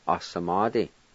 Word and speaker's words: asamadi: And it's spelled A, S, A, M, A, D, I. A, S, A, M-A-D-I. Number two, smoke. And it's spelled T-S-U-G-A-S-V-S-D-I. T-S-U-G-A-S-V-S-D-I.